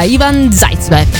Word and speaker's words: Ivan Zaitsev: quindi tanti auguri a Romina e a Ivan Zaitsev. L'ho detto bene? – Hai detto benissimo.